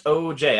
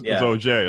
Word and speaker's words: Ogier: It's actually pronounced OJ. I know it's I got it wrong too. I was like, oh yeah, it's like French, right? I was like, no, it's OJ.